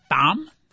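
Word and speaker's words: Thom: Tom? The guy's name is Tom. I didn't know the H is silent.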